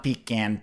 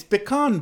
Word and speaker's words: pecan: Okay, what's the proper pronunciation for pecan? – It is pecan. – Or pecan. – It's not pecan pie. – Pecan pie. – It's pecan pie, of course.